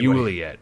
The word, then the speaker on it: Joliet: In Joliet, Illinois, which sounds a little French to me, I don't know. – Anyway. Juliet, I believe the locals pronounce it.